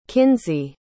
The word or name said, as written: kinsey